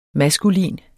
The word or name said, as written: maskulin